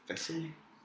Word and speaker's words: thistle: Which letter in the word thistle is silent? – What? Thistle? – Is it the H? – No, it's the T. – Wait, what? How? – There's a second T in it. – Thistle. – Yeah, thistle. There's a T-L-E at the end.